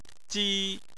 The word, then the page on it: ji1: chinese